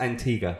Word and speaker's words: Antigua: Pronounce them all very well, apart from Antigua. – Antigua. – It's Antigua. – Ah, Antigua.